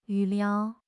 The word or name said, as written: 预料